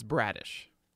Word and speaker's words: Bradish: Is it Bradish or Bradish? How do you pronounce it? – It's Bradish.